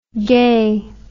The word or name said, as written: gae